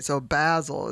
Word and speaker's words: Basil: Basil, it says, rhymes with dazzle, as in his homeland.